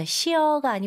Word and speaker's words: sheer: 끝에 R 발음을 강하게 해주셔야 됩니다. – Mm-hmm. – 그래서 sheer가 아니고 sheer.